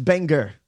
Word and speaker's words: Bangor: No, it's pronounced Bangor.